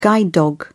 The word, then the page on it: guide dog: British English